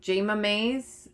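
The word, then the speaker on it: Jayma Mays: And we cut to the linen store with, I'm sure I will pronounce her name wrong, but is it Jemma Mays? – Yes, Emma from Glee.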